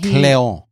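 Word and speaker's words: Kleon: Well, um, Austin, I think it's Cleon. Is how you say his name. Uh, he, Cleon. Yes, Cleon.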